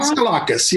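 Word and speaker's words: Daskalakis: And we have out gay Dr. Dimitri Deskalakis. Is that right? Did I say it right? – Deskalakis, yes.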